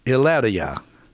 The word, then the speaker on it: Hilaria: And her name is Hilaria, is how it's pronounced. H-I-L-A-R-I-A, with the Spanish pronunciation, because she's Spanish.